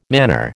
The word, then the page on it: manor: American English